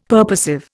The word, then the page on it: purposive: Indian